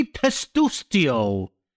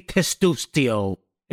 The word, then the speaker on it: Testuccio: It's pronounced Anthony Testuccio. You have been butchering my name. As long as I've known you, you've never said my name right. It's Anthony Testuccio. It's all hard T's.